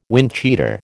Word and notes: windcheater: American English

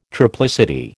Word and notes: triplicity: American English

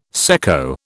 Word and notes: secco: British English

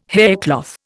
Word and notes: haircloth: Indian

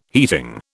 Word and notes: heating: British English

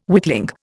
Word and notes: witling: Indian

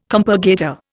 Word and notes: compurgator: Indian

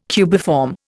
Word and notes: cubiform: Indian

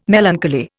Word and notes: melancholy: Indian

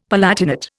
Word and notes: palatinate: Indian

